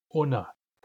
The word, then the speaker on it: una: In most dialects, the question word for how many is una, with a u in the first syllable, una. Only in East Barkley Sound, and sometimes in West Barkley Sound, the word is pronounced ana.